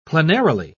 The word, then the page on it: plenarily: British English